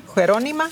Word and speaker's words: Jerónima: Y ahora quisiera leerles de, Jerónima. Nos cuenta ella lo Creo que es Jerónima, ¿no? – Jerónima. – Jerónima. – Oh, Jerónima. – Ajá, ajá. – Acento en la O.